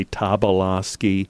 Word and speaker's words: Tobolowski: everyone in our family pronounces it differently. They say Tobolowski, Tobolowski, Tobolowski. I say Tobolowski. everybody pronounces it differently.